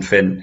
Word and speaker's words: Finn: Yeah, so the name of my YouTube channel is Jonathan Finn Hypnosis.